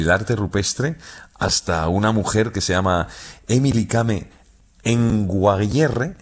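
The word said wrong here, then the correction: Emily Kame Kngwarreye: lo he dicho mal. – Emily Kame. – Con Guarreye. – Emily Kame con Guarreye, sí.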